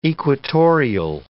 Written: equatorial

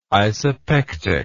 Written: isopectic

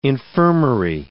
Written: infirmary

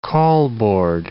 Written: call-board

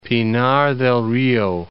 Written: Pinar del Rio

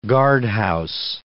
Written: guardhouse

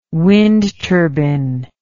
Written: wind turbine

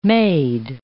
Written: maid

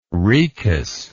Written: Rhoecus

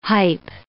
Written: hype

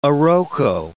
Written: iroko

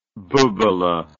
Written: bubeleh

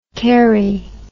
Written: kerrie

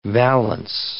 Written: valance

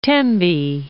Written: Tembi

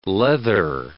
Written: leather

